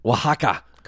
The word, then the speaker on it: Oaxaca: Okay, 23rd of December in the Mexican city of, I don't know how to pronounce Oaxaca. – That. Oaxaca.